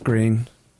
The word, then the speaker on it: Groen: That's green. – Green? – Yeah, it's pronounced green.